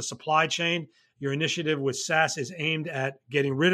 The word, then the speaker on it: SAS: called SAS, so they pronounce themselves as SAS, which is a I guess I would describe them as a uh, provider of data services, uh, software that analyzes data.